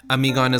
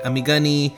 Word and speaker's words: Amigon: Amigon is a combination of the words, the Urdu word for mom, which is Ami, and the Greek tragedy Antigone, Amigon. But people say Amigani, am I gone?